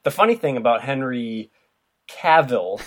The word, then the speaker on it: Cavill: Henry Cavill had actually... Or Cavill, sorry. Or whatever it is. – When I looked up the phonetic pronunciation, it said, like, travel. So it's Cavill, I guess.